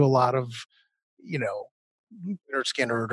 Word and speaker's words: Lynyrd Skynyrd: And so for me, I guess the best album of all time is probably Lynyrd Skynyrd's, pronounced Lynyrd Skynyrd is actually the title of it.